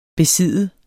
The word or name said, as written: besidde